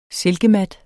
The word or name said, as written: silkemat